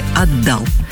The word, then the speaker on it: отдал: Сегодня в рубрике «Говорим на русском» разберемся, как правильно говорить. Отдал или отдал. Невероятно, но факт в разговорной речи допустим оба ударения, а для работников радио и телевидения есть предпочтительный вариант, где ударение ставится на первый слог «отдал».